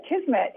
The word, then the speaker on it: Kismet: Before we get to the music, right, did I pronounce Kismet correctly, by the way? – Yes, I did. Kismet.